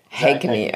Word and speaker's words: Haigney: Haigney.